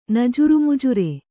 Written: ನಜುರುಮುಜುರೆ

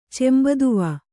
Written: ಚೆಂಬದುವ

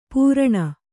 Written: ಪೂರಣ

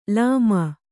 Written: ಲೋಮಿ

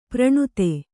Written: ಪ್ರಣುತೆ

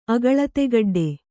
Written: ಅಗಳತೆಗಡ್ಡೆ

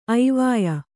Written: ಐವಾಯ್

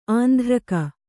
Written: ಆಂಧ್ರಕ

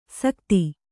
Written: ಸಕತಿ

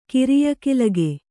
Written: ಕಿರಿಯಕಿಲಗೆ

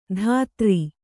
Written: ಧಾತ್ರಿ

